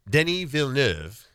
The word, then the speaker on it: Denis Villeneuve: And Denis Villeneuve, which is how you say his name because he's French-Canadian. – Sure, okay. – Denis Villeneuve, the great director.